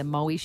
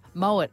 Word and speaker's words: Moët: And the guy looked at me and I'm like, no, but I'm saying it right. And he goes, it's Moët. And I'm like, no, but it's actually Moët. And then I realised now by saying it right, because it's known as Moët, everyone's going to think when I say Moët that you're an absolute idiot.